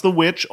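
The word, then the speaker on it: The VVitch: It's pronounced The Witch, although it's spelled with two capital V's instead of a capital W.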